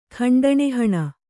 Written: ಖಂಡಣಿಹಣ

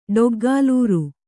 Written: ಡೊಗ್ಗಾಲೂರು

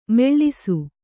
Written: ಮುಳಿಸು

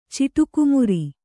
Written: ಚಿಟುಕುಮುರಿ

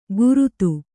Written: ಗುರುತು

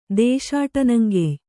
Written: ದೇಶಾಟನಂಗೆಯ್

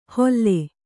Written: ಹೊಲ್ಲೆ

